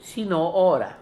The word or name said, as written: Sino ora